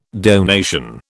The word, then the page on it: donation: British English